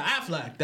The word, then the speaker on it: Affleck: Yeah, Affleck.